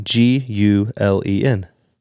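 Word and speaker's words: Gülen: And last name G U L E N.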